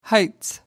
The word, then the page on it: heights: British English